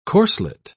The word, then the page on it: corslet: British English